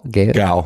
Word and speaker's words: Gal: Gal? – Gal.